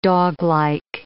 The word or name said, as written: doglike